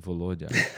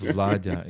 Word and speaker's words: Volodya: And if you look at the Russian language, the way how the diminutive name from Volodymyr is pronounced, even in Russia, meaning Volodya, it's not pronounced Vladya, it's pronounced Volodya.